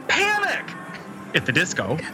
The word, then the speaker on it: Panic! At the Disco: Panic! At the Disco are performing. – That's how you say it.